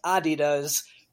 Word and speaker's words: Adidas: The other thing is Adidas, which is actually pronounced Adidas, was a massive European company, way bigger than Nike was at the time.